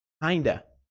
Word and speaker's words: kind of: Kind of, muitas vezes é pronunciado, kinda.